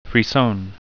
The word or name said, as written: frisson